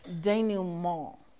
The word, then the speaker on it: denouement: So, we're going to learn a French word. Denouement. You have to say it like that and kind of flick your nose up because it's French, you know, denouement.